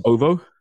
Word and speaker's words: OVO: B, it's OVO. Pronounce it OVO.